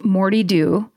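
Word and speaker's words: Moddey Dhoo: My personal favorite is the Moti Doe. Oh, sorry. Pronounced Morty Doe.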